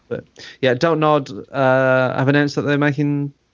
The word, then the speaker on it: Don't Nod: How do you say their name, the development? – Don't nod. – Is it just don't nod? Right, okay. – I mean, it's just don't nod.